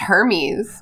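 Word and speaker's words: Hermès: See, it's pronounced Hermes.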